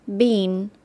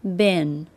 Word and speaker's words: been: Listen to the difference British, bean, American, bin. Since